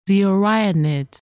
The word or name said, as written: Orionids